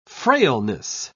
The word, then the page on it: frailness: British English